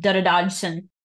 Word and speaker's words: Dodgson: he actually would pronounce his name dodson and so when the biography was entered in Encyclopedia Britannica, it was actually inserted right before the entry on Dodo.